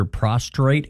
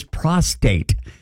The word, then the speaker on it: prostate: Guys, I mean, have you been in for your prostrate exam lately? – Oh, I know. – It's prostate. Not prostrate.